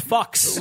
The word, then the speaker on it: Fuchs: Or D, Judge Fox. – Worst kid show ever. Yo-Yo Davalillo. – Did you say Judge Fox? – Yes, it's spelled F-U-C-H-S.